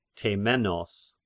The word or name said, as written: Temenos